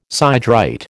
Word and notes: siderite: American English

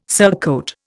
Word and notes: surcoat: Indian

